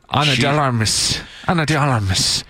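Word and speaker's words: Ana de Armas: Anna de Armas, I think I said her name right. – Anna de Armas.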